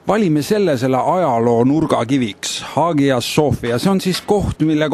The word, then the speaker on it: Hagia Sophia: Kas sinul ütleb midagi, kas ma hääldan õigesti Hagia Sophia? – No, sina ütlesid seda praegu ilmselt kreeka keeles, et Hagia Sophia, et püha tarkuse kirik Istanbulis. Ja türklased on seda natukene türgistanud, nad ütlevad Hagia Sophia.